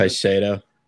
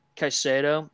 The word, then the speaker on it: Caicedo: Caicedo. – Caicedo.